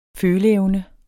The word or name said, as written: føleevne